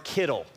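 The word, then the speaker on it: Kittel: There's a man named Gerard Kittle, and you can remember it because this is Kettle. His name's Kittle, and it rhymes with Skittle, okay?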